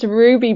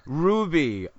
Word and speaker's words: Rouxbe: That's Ruby. – Ruby, R-O-U-X-B-E. That's right.